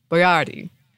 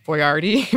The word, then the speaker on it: Boiardi: So disclaimer out there, I'm going to try to pronounce it closer to the Italian way, but I feel that Boiardi?